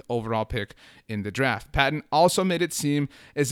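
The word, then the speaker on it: Paton: NFL Network's James Palmer noted on Thursday that Denver Broncos general manager George Patton, I think people pronounce it Payton, but Patton sounds so much cooler, noted that they have not, the Denver Broncos, made any calls about moving up in the NFL draft.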